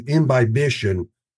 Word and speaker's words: imbibition: As it deteriorates, some of the restrictions to imbibition are gone. Is that right, John? Am I saying that right? – Yeah. So I think,